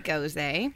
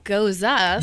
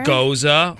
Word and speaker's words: Gose: Rosé Gose, which is actually a Goza. – Yeah, actually, it's beer. – Pronounced Goza.